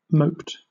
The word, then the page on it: moped: British English